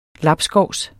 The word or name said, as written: labskovs